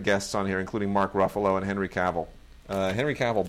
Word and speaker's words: Ruffalo: Just by the way, in watching Mark Ruffalo on Letterman, yep. – I learned something very important. – Yes. – It is Ruffalo, rhymes with buffalo.